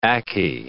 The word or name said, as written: akee